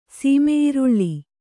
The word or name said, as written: ಸೀಮೆಈರುಳ್ಳಿ